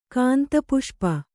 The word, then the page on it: ಗಂಧಪುಷ್ಪ: Kannada; gandha puṣpa